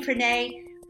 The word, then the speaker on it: Frene: My name is Frene Lesek, and I'm here in West Australia. My name Frene rhymes with the word Rene, if you've always wondered how to pronounce it.